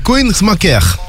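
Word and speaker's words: Kœnigsmacker: Alors, Koenigsmacher.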